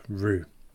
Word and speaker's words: Rous: But for those of you that cannot wait, I would firstly suggest Rous. Probably an awful pronunciation. It's spelt R R-O-U-S, Rous.